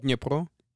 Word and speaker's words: Dnipro: How do you say that? – Dnipro. – Okay. Well, he says it the Ukrainian way.